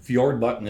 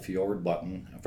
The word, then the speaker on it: Fjordbotten: But Fjordbotton is my last name, Paul Fjordbotton, from the fjords of Norway.